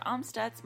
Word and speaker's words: Amstutz: And you can find me everywhere at Sandra Amstutz.